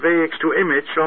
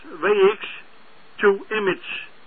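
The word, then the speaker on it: WXtoImg: En er wordt uitgesproken als WX to Image. Zo wordt er uitgesproken, denk ik.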